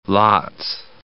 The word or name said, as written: lats